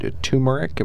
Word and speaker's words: turmeric: And then post-workout snacks, a protein shake, carrot, turmeric. Am I saying that right? – Yeah, mm-hmm.